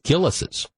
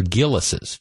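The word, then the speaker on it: Gilles: But they pronounce the name of the custard, they pronounce it Gillis's. G-I-L-L-U-S-E-S. That would be how, I understand it's G-I-L-L-E-S, which is how it's spelled, but it's pronounced as if it were Gillis's. G-I-L-L-U-S-E-S.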